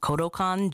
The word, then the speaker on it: Kodokan: Kodokan Judo. – Oh, you say that right. I like the way you say that. Kodokan Judo.